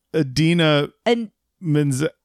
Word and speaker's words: Idina Menzel: But two of the people were Kristen Bell and Idina Menzel. Am I saying her name right? I always get nervous because. – No. That's not, her name's. Idina Menzel, because you want to say Adele.